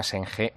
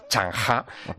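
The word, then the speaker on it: Zheng He: Hay una historia que es maravillosa, es un personaje que se llama Shenghe, bueno, en realidad si lo tengo que pronunciar a la china es Changha.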